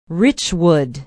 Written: Richwood

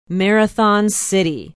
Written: marathon city

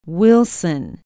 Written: wilson

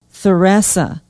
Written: theresa

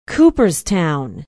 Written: Cooperstown

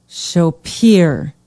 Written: Shopiere

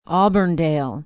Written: auburndale

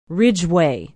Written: Ridgeway

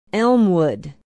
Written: elmwood

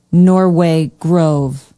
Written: norway grove

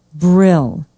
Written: brill

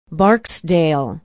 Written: barksdale

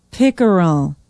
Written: pickerel

